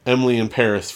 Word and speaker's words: Emily in Paris: So we watched Emily in Paris, which Netflix just put out a tweet that that's how you're supposed to say it. – So it rhymes. – So it rhymes. – Emily in Paris, for those that aren't familiar with the French.